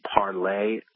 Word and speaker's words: Parler: It's called Parler, although it's spelled Parler.